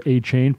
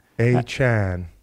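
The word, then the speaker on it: Achane: At Dolphins rookie minicamp this offseason, before Monday's update, A-Chan said a reporter was correct when pronouncing it A-Chain. He was also known as such while a standout at Texas A&M before he was drafted in the third round of this year's NFL draft. The topic came up when ESPN's Adam Schefter said on live TV that the correct pronunciation was A-Chan, to blow back from the hosts.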